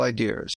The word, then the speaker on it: idea: This is our practice too to keep moderation, especially in harmful ideas. Are you going am I pronouncing ideas wrong? That's yes. Yeah. – Idea. It doesn't have an arm in the end.